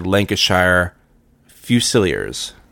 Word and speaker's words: Lancashire Fusiliers: They're in the Lancashire Fusiliers.